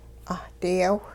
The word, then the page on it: 'ahdiyaw: hupa